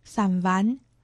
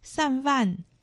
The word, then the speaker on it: 三湾: 在今天节目中介绍客家文化重点发展区苗栗县三湾乡，客家话四线腔发音散丸，客家话海陆腔发音散饭，欢迎听众朋友们一同收听。